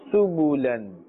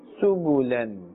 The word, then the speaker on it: سبلا: Here also this ba, ba with its dhamma must be pronounced clearly. – سبلا سبلا سبلا فجاجا ثمنا قليلا ثمنا قليلا فتح قريب فتح قريب رسول كريم رسول كريم كراما كاتبين كراما كاتبين.